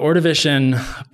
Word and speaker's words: Ordovician: Ordovician is the first one. Is that how you say it? Yeah. So the Ordovician period, it's after the Cambrian.